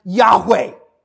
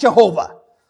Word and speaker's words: Yahweh: This is absolutely crucial to understanding who God is to understand this name that in the original language is probably best pronounced Yahweh, not Jehovah. Yahweh is the best way to pronounce this name.